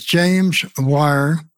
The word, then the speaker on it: James Wire: My name is James Wire. It's spelled J-A-M-E-S, then initial R, and W-I-R-E.